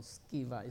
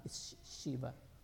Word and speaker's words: Sceva: Now, if you are reading Hebrew words, Hebrew names, letter C is pronounced K. So Sceva is not Sheva.